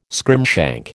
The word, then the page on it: scrimshank: American English